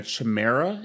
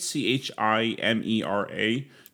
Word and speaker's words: chimera: They kind of said that there's two what they call building a chimera is how you say it c h i m e r a chimera, if okay.